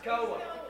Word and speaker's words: Tekoa: And it says, early the next morning, the army of Judah went out into the wilderness of is that Tekoa? How you pronounce that? Somebody. Nobody? Tekoa. Huh? – Tekoa. – Okay. Yeah. That's it right there. I pronounced that like five different ways and none of them sounded right.